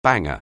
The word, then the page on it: banger: anglais